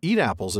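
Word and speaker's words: Étaples: Etaples. I'm trying desperately not to mispronounce that. Eat apples, as it was known to the insular Tommy. I could have just pronounced it that way.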